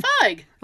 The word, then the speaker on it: Feig: Feig.